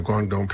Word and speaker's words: Guangdong: Pronounce it Guangdong province of China.